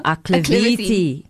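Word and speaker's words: Aklivity: No, acclivity.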